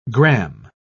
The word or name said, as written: gram